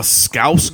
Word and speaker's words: Scouse: Oh, S-C-O-U-S-E and then girl. – Oh, okay, okay. That was, I did say it right.